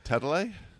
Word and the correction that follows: Talalay: Is it? – Talalay, yeah, okay, yeah. Rachel Talalay.